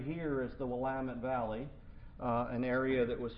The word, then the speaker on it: Willamette: It's called Willamette. That's how we pronounce it.